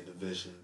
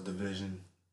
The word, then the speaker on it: DVSN: Like I used to say Division, DVSN, till I got corrected and told it was Division.